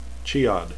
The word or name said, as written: chiad